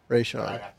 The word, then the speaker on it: Raishan: I think it was Rashan. – Raishan. Okay. – R A I S H A N.